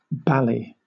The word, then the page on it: bally: British English